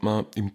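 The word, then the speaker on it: Maniq: Ja, also eigentlich sagt man Mani. Also das Q spricht man eigentlich nicht. Das schreibt man M-A-N-I und das Q eben, aber das ist stumm. Das spricht man nicht aus, sondern man bleibt mit der Stimme so stehen quasi. Mani.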